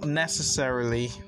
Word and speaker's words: necessarily: Also, we have the stress on the first syllable, so you could use in the sentence by saying it's not necessarily the answer, okay?